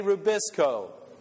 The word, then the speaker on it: Rubisco: Say Rubisco.